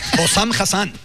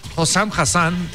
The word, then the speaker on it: Hossam Hassan: Vamos a escuchar al entrenador egipcio, Hossam Hassan o algo así. – Hossam Hassan. – Hossam Hassan, disculpe.